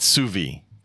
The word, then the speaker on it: sous vide: Once I saw what it was, I was like, oh, now I know how to pronounce that. It's sous vide, by the way.